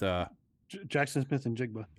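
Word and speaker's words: Jaxon Smith-Njigba: Because of where you have Jaxon Smith, how do you say it? Jaxon Smith. – Jaxon Smith and Jigba. – And Jigba, okay.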